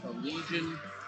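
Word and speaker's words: Allegaeon: A far more commonly known band, spelled A L L E G A E O N, I believe, pronounced Allegion, off the album Damnum.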